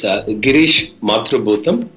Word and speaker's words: Girish Mathrubootham: How did I do with the name, Girish? – Pretty good for the first time. – Can you pronounce it right? I want to be accurate. – Yeah, it's Girish Mathrubootham.